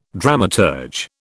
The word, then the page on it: dramaturge: British English